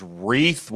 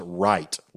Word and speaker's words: Wright: Aniston, what we thought was Reith, what we've pronounced the whole time like that from Harbor, the pitcher that's now committed to South Alabama. I learned this weekend that her last name is pronounced Wright, like R-I-G-H-T.